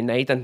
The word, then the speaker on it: Nathan: But when I'm saying my name, I would just say Nathan, you know what I mean? Like, yeah. – And I tried to pronounce the T and the H, and I sometimes just say, Nathan, you know what I mean? – Yeah.